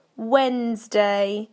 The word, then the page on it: Wednesday: British English